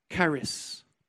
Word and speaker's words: charis: And it's literally called charis. It's spelled C-H-R-I-S, but it's really like a K.